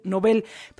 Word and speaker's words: Nobel: Es que por aquí me dicen, y lo agradezco, me dice por acá el Jorge Luna, me dice la palabra Nobel, es aguda, es sin acento, con sílaba tónica, Bel, Nobel.